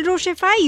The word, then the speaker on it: Rochie: Then why don't you hit me up, make that confession and I will रोशिफाई you. और वो करने के लिए आप मुझे ढूंढ सकते हो इंस्टा पर या एफबी पर. मेरा नाम है मिर्ची रोशी, नाम की स्पेलिंग है ROCHIE.